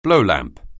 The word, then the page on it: blowlamp: anglais